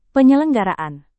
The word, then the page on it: penyelenggaraan: indonesian